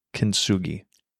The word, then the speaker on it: Kintsugi: It's called Kintsugi pottery. – What? – I think I'm saying that right. – Kintsugi? – Kintsugi. It's actually Japanese.